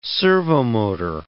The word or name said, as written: servomotor